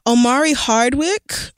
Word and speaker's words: Omari Hardwick: Omari Hardwick, however...